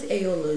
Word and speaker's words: Ayoola: Ayola or Iola. How is that pronounced? – It was Ayola is how they pronounced it in the audio.